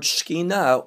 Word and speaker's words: Shekinah: Most of you know the word Shekinah or Shekinah in English is normally how it's pronounced.